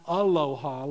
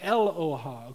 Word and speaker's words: Eloah: It's the plural of a singular word called, or pronounced something like Aloha, not Aloha like you're in Hawaii, but Eloha, okay? E-L-O-A-H.